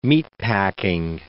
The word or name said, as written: meatpacking